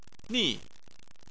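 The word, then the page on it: ni: tone 4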